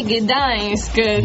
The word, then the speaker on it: Gdańsk: C'è anche Gdańsk della Polonia.